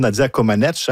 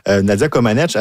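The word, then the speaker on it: Comăneci: On parle d'un spectacle de variété auquel assistera bien sûr Nadia Comaneci. Hein. On a appris cette semaine qu'on ne plus prononcer le I. Mm-hmm. Alors, c'est 49 ans d'erreur. Et là, finalement, on rectifie le tir.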